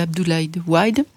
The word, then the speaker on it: Abdoulaye Wade: À l'époque, c'était Abdoulaye Wade. La prononciation n'est pas... – Oh si, vous êtes parfaite, voilà.